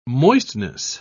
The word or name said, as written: moistness